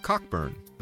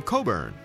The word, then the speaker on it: Cockburn: Being Americans, we naturally pronounce the name Cockburn, the way it's written, with a hard CK sound in the middle of the word.